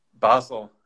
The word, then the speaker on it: Basel: Is it Basel or Basel? – Basel.